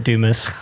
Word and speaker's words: Dumas: Go for it, Dumas. I pronounce that. – Oh, yeah. Everybody pronounces it the other way.